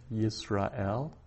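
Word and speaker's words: Yisrael (ישראל): So we have sar, sar, and sare, sare, and Yisrael, Yisrael, where that shva under the sin is silent, Yisrael.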